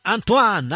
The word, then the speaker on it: Antoine: Pure la cantava pure uno francese, Antonini. Antonini. – Forse Antoine. – Antoine, scusa. Che pronuncia che ciò? Antoine, sì. – Antoine, non Antonio.